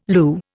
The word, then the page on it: Lu: Indian